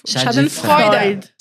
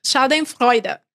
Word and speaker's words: Schadenfreude: Schadenfreude. Schadenfreude.